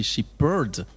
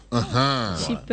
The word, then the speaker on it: Shepherd: Je pense que la bonne prononciation, c'est Shepherd. – Shepherd.